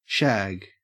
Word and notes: shag: Australian English

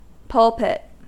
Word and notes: pulpit: American English

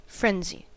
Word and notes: frenzy: American English

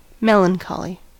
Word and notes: melancholy: American English

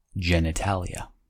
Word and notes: genitalia: American English

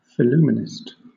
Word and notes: phillumenist: British English

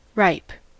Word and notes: ripe: American English

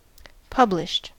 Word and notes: published: American English

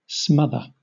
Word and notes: smother: British English

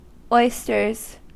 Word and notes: oysters: American English

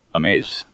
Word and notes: amaze: American English

